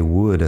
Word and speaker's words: would: Would, could y should, y la L no suena en ninguno. He dicho en otro momento que would es posiblemente la palabra más difícil del inglés porque he pasado muchísimo tiempo Ah, intentando conseguir una buena pronunciación de esta palabra de mis estudiantes, es difícil la W al parecer y la E, que es una vocal corta, y también no suena.